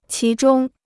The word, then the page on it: 其中: qí zhōng